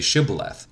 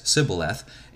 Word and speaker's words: Shibboleth: Then they say Shibboleth, which is a word that they knew that the Ephraimites could not pronounce because they had grown up from such a young age pronouncing it with the S instead of the SH sound as Sibboleth, and they could not pronounce it Shibboleth,